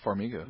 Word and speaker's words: Farmiga: Runner-up, Vera Farmiga, or Farmiga? How do we say that? – I believe it's just Vera Farmiga.